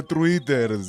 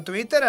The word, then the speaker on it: Twitter: No se dice Twitter, se dice Twitter.